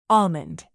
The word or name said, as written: almond